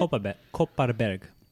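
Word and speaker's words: Kopparberg: Copaberry, and there's an Irish one. Yeah, Copaberry, exactly. How you say it? – Copaberg. Exactly. You've been there.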